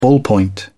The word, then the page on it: ballpoint pen: British English